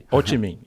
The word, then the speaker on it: Ho Chi Minh: And, you know, cities like Los Angeles, Ho Chi Minh, I don't know if I'm pronouncing that right. – Ho Chi Minh, yeah.